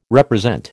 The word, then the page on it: represent: American English